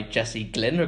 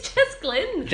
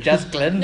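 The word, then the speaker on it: Jess Glynne: So I get that exact same one from Darling Hold My Hand by Jessie Glynn or Glynn, whatever you pronounce her. – Glynn. – It's Jess Glynn. – Jess Glynn.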